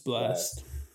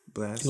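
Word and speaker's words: Blxst: Yeah, I think it's Blast. – Blast.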